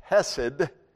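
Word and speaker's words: kesed (חֶסֶד): And then the third word is pronounced kesed, and that's a word that's generally translated loving kindness.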